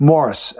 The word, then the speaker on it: Maurice: Yes, that Maurice Gibb of Morris, actually, I believe is how they pronounced his name,